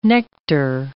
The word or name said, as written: nectar